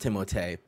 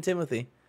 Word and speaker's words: Timothée: Nick, in the correct French, it is pronounced Timothée, but in some interview he came out and he was like, yeah, just it's always been Timothy.